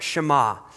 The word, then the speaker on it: Shema: And did you know that in Hebrew, that word is pronounced Shema?